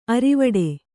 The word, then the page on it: ಅರವುಡಿ: Kannada; aravuḍi